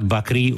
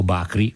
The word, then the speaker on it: Bakri: di un grandissimo artista che si chiamava Mohammad Bakri o Bakri, insomma a seconda di come uno lo vuole pronunciare, e che era un attore e regista palestinese con cittadinanza israeliana.